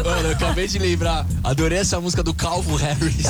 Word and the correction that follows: Calvin Harris: Eu falei errado. Eu falei Calvo Harris? – Falou, não falou, Yas? Eu falei Calvo. – Falou Calvo Harris. – É Calvin Harris.